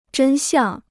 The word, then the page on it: 真相: zhēn xiàng